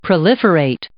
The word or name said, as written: proliferate